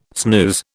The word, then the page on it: snooze: British English